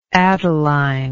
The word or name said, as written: adeline